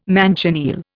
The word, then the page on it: manchineel: Indian